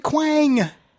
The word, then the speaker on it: Kwang: K-W-A-N-G. – A-N-G? – Yes. Look, I don't make these things up, people. – That's not phonetically correct. – I can't help that. Take it up with Quain. – A-N-G? That's Quang. – Whatever. Quang. – You don't know how to pronounce it. – Okay, then Quang. – Okay. That now it makes sense.